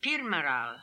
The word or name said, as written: Pirmaral